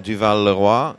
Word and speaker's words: Duval Leroy: I'm the export director for Champagne Duval Leroy in Vertu, France. – Excellent. You pronounce it so much better than I do.